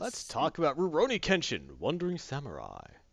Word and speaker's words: Rurouni: You're supposed to roll both those R's. – Well, it's like an LR sound. – Yeah. – Rurouni Kenshin. – No, Rurouni. – Rurouni Kenshin.